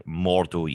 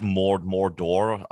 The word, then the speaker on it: Mordo: Mordo, yes. Mordo, yes. That's exactly it. I had Mordor, I was going to say, or something like that, but I was like, maybe I'm going to pronounce it wrong there.